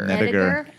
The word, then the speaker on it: Nediger: Hard G, by the way. – Nediger.